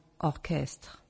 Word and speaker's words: orchestre: Sometimes sh is pronounced k, as in orchestre, orchestra. Ga, go, gu, as in guitar, guitar.